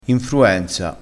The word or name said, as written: infruenza